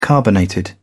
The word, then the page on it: carbonated: British English